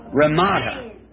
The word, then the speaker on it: Ramada: Ramada.